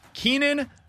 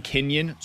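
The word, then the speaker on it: Keynan: Keenan Middleton. I want to say Kenyon so bad when I look at the way that his name is spelled. It's K-E-Y-N-A-N. So Keenan. Keenan Middleton is the newest addition for the St. Louis Cardinals.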